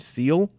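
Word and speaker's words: Siehl: Alexia, I'm going to have trouble with this, Seel, S-I-E-H-L. I'm going to imagine yeah. it's very much a German pronunciation, Seel.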